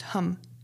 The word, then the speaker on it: ham: So hum, H-A-M, but pronounced hum.